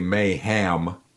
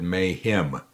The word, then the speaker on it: mayhem: Excuse me, but I didn't say mayhem. I said mayhem, which the way I pronounce it, it rhymes with him, you know.